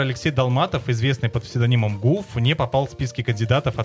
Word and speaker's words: Гуф: Гуф. Так и читается. – И Гуф. – Но не гю, не по-французски.